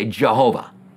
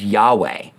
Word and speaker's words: Yahweh: came up with Yehovah, or as the Jehovah Witnesses say, Jehovah, which it would not be pronounced that way. A better translation is Yahweh.